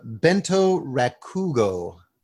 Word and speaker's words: bento rakugo: So tell me about, what is a bento rakugo? – Ah, am I saying that right? – Yes, you're saying it perfectly.